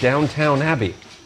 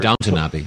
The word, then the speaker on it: Downton Abbey: Now, you just said Downton Abbey, which whenever Americans say that, we get corrected. And we're Downton Abbey. – Downton Abbey. – Yeah. I was Americanizing myself.